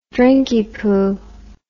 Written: drinkypoo

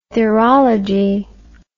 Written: therology

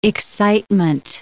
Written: excitement

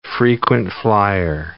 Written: frequent-flier

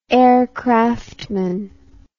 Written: aircraftman

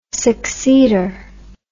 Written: succeeder

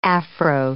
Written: Afro

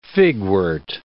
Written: figwort

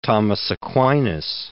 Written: Thomas Aquinas

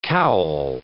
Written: cowl

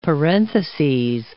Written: parentheses

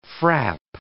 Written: frap

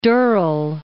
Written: Durrell

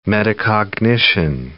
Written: metacognition

